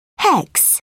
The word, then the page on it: hex: British English